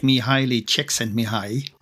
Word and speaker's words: Mihaly Csikszentmihalyi: Yeah, well, it's always, you know, it's a challenge, certainly when you have Hungarians in the room, but it's something like Mihaly Csikszentmihalyi.